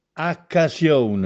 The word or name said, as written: Accasiòne